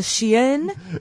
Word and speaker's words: chien: You'd rather have a chien instead of me? – Yeah. – A banker? That's how you say it in French.